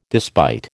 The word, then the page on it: despite: American English